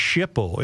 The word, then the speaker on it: SHPO: The mansion is on the National Register of Historic Places and thus is under the eye of the State Historic Preservation Office, or SHPO, pronounced SHPO in government acronym alphabet soup lingo.